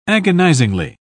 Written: agonizingly

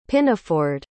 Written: pinafored

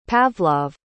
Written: Pavlov

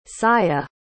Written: saya

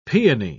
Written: peony

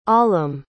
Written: Aalam

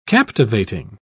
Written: captivating

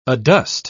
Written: adust

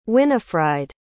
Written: Winifride

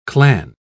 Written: clan

